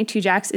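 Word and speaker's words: Tujague's: And interestingly, Two Jacks is spelled T-U-J-A-G-U-E, apostrophe S. So not exactly how you would think of Two Jacks, but that's how you say it, apparently.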